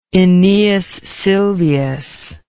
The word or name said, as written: aeneas silvius